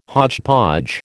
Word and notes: hodgepodge: American English

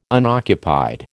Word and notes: unoccupied: American English

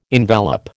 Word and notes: envelop: American English

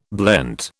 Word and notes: blent: British English

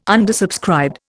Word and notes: undersubscribed: Indian

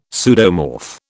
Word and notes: pseudomorph: British English

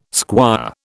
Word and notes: squire: British English

